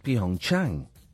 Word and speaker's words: Pyeongchang: It's pronounced Pyeongchang. – Oh. – Yeah, everyone's saying Pyeongchang now.